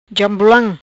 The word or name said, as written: Jambulam